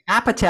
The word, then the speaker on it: Apatow: Apatow? – Apatow.